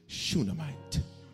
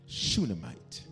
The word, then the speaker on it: Shunammite: But for those of you who do want to spell it, it's S-H-U-N-A-M-M-I-T-E. Shunammite. Shunammite.